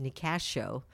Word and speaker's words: Nicasio: It's about 25 minutes from Petaluma in the little sleepy town of Nicasio, which is actually pronounced Nicasio. Because it's named after a Native American.